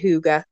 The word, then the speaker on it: hygge: And I was saying it wrong for the longest time. But it is actually hygge.